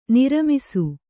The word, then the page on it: ನೆರ್ಮಿಸು: Kannada